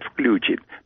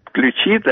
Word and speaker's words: включит: Очень многие люди говорят «включит». Мне, например, лично не нравится такое произношение.